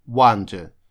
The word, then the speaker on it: wander: У нас есть еще похожее слово, которое пишется через букву O и произносится практически так же, но оно означает удивляться. Вот слово wonderful – удивительный, как раз образовано от слова wonder – удивляться, а это wander, то есть пишется через a, означает блуждать, бродить.